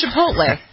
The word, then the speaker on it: Chipotle: It's Chipotle? Is that how you pronounce it? – No, it's not the it's just Chipotle.